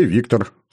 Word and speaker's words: Виктор: Для друзей Виктор, с ударением на «о».